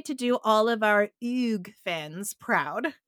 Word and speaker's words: Hugues: I will say that I did some research, and the correct way to pronounce Hugues' name is Hugues.